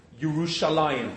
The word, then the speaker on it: Yerushalayim: I mean, the name itself, very clearly, even though it's written Yerushalayim with Shin Lamed Mem at the end, is always pronounced Yerushalayim.